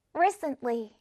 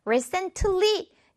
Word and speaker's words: recently: 자, 이거 발음하실 때 recently 이렇게 하면 이게 오히려 더 힘들어요. 그냥 recently, recently 이렇게 트 발음을 꿀떡 먹으세요. 그냥 recently, recently 이렇게 발음하시는 게 더 편합니다.